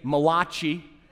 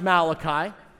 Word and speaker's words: Malachi: They appear in the last book of the Old Testament, Malachi, or as people in New Jersey like to say, Malachi, the Italian prophet, right? Malachi. It's pronounced Malachi.